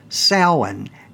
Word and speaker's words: Samhain: So you have Samhain, kind of nonsensical, but that way I started pronouncing the word correctly as Samhain, S-O-W-I-N.